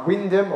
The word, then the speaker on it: Wymondham: And the place where we are now is just 8 miles south of Norwich in a market town called Wyndham, or Wymondham as it's spelled. And we planted that church about 12 years ago, started then.